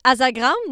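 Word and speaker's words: Asagraum: Elle a fait partie de moult projets musicaux avant de joindre Crypta, dont le groupe Azagran, que je vais prononcer Azagran, permis hein, par le groupe, la chanteuse admet que la prononciation peut différer entre le néerlandais et le vaste monde.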